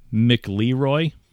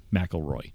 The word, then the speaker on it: McLeroy: his name was Zach uh, it spelled McLeroy, but I looked it up on pronunciation pages and it says McElroy. – Yeah, it's McElroy. Yeah. – So it looks like McLeroy, which would be total Southern, but it's McElroy.